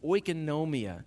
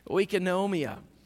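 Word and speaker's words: Oikonomia (οἰκονομία): Oikonomia is another one of those words. Oikonomia. I'm going to repeat that because I said it right. Oikonomia is household management.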